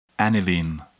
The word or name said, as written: aniline